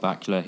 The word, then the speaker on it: Bakula: Scott Bakula, his name rhymes with Dracula.